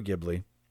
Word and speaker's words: Ghibli: but the second one, I know Studio Ghibli. That's how you pronounce it, right? – Yeah.